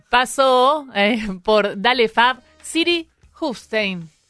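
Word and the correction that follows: Siri Hustvedt: Siri Husbet.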